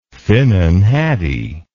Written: finnan haddie

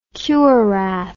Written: kurath

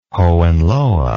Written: Hohenlohe

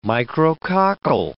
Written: micrococcal

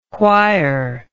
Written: why're

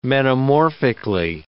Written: metamorphically

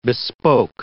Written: bespoke

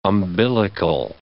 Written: umbilical

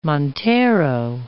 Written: montero

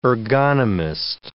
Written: ergonomist

